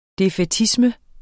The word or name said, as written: defaitisme